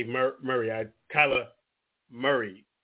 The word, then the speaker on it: Kyler Murray: And I did say Kyler Murray is the way it's pronounced.